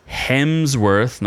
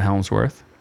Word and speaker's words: Hemsworth: Well, Extraction 2, starring your favorite Thor and mine, Chris Hemsworth, not Helmsworth, as I've mispronounced numerous times in the past.